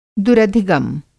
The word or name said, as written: दुरधिगम